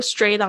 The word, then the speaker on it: straight: 嗯，呃，go straight on 就是一直继继续走，直直走。